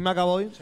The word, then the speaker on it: McAvoy: Entonces está, eh, ahí está este, Macaboy. – ¿Lo dije bien? James McAvoy.